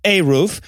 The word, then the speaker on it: eruv: First up, what is an eruv? – It's actually, I know this one, and it's pronounced a roof.